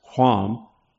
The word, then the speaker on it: Ham: Ham, pronounced Quam, signifies hot, and Japheth signifies expansion.